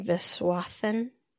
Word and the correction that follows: Viswanathan: Viswanathan? – Yeah. – Geraldine Viswanathan?